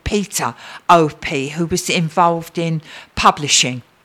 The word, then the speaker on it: Opie: It's Iona Opie, spelled O-P-I-E.